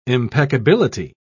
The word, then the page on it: impeccability: British English